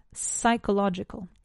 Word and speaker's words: psychological: The P in English is also silent. Psychological.